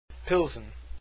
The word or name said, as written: pilsen